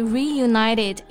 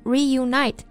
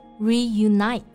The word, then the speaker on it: reunite: Yeah, anyway, they reunited in 2019 with their hit Hello My Love. 2019年呢，西城男孩就重新聚首，发表了新歌 Hello My Love. – 嗯，刚刚我们讲了解散怎么说啊，那也有不少的组合呢，他们解散了之后又再次聚首了。那重组、复合，我们就用 reunite 这个动词。 – 对，大家要注意这个单词的发音，字母 I 在这里呢是发刷音，字母 I 在这里呢是读双元音 I, reunite。 – 对，不要读成了 reunit，是 reunite。